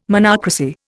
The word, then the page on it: monocracy: Indian